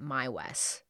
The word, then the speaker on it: Meiwes: His last name is spelled M-E-I-W-E-S. And in the documentaries I watched, they were all pronouncing it Maiwis.